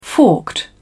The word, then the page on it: forked: British English